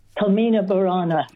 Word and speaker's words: Carmina Burana: Carmina Burana.